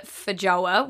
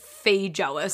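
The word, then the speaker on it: feijoa: Also, you'll notice that me and Nick pronounce it Fijoa. We're from the South Island. We grew up calling it Fijoa, but everyone up in the North Island calls it Fijoa. So it's probably irking a lot of you. I just wanted to give you some context on why we say it like that.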